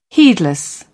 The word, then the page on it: heedless: British English